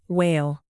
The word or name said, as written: wail